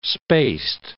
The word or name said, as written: spaced